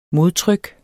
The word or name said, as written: modtryk